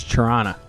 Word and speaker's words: Toronto: You said it wrong. It's Toronto.